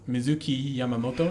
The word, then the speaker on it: Mizuki Yamamoto: Cast was Mizuki Yamamoto. Am I saying that correctly? – Yep.